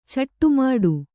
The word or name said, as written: ಚಿಟ್ಟೆಮುಟ್ಟಿ